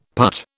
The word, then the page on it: putt: British English